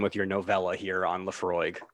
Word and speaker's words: Laphroaig: So as I have said, I am over here drinking Laphroaig 10-year-old Isla single malt Scotch whiskey. – Yay! – Yes, I pronounced all of that correctly,